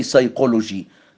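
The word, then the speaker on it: psychology: انما كلمه بتبدا ببي اس زي سايكولوجي، لا ننطق البي،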